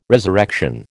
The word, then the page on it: resurrection: American English